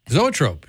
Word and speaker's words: Zoetrope: I don't know how to say this in the Zoetrope. – Zoetrope, yeah.